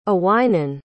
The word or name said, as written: aweinon